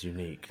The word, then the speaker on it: Eunique: That's spelled E-U-N-I-Q-U-E. – That is unique.